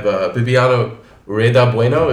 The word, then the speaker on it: Bibiana Rueda-Bueno: We have Bibiana Rueda-Bueno.